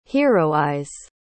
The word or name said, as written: heroize